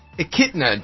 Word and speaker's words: echidna: When the manic doctor of Robotnik returns to Earth with a new ally, Knuckles, the Echinada. – Echidna, Jerry. Echidna. It's a tribe. Play some Sega. – It looks like it's spelled like Enchinada. – Okay, it's not.